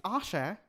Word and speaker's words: Aashay: So it's Aashay, not an ashtray. – Nice. – I know. I'm printing a t-shirt very soon. – You should. – I should. Many people find it difficult to pronounce my name. Even though it's just two syllables. – Yeah. – It's Aashay and Share.